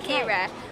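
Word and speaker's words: Keera: It's K-E-E-R-A. So not many places... – Katie? – No, it's Kira.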